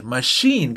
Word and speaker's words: machine: La ch también a veces hace como sh. Machine, que es una máquina.